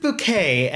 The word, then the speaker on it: Bucket: Hyacinth Bouquet, and her last name is spelled Bucket.